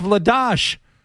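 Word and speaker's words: Vladař: And Vladar? Vladash? – He knows the players. Yeah. But we've heard Vladash, like it's supposed to be pronounced Vladash.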